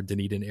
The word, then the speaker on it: Dunedin: Took an internship with the Toronto Blue Jays down in Dunedin, Florida, Clearwater, Dunedin area, for those not aware. And it is pronounced Dunedin.